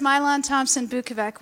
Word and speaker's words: Mylon Thompson-Bukovec: It's also really hard to pronounce, so let me break it down. It's Mylon, which is Vietnamese, because I'm half Vietnamese. It's Thompson, because my dad comes from Ohio.